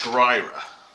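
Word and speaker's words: Skryre: I think it's pronounced Skryra. – Skryra. – Skryra. – Wow. Skryra. I've been saying that wrong forever.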